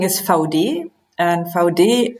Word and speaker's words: Vaude: Managing Director of German hiking and biking company Vaude, or, as you'll soon find out, how it should be pronounced, Vaudi.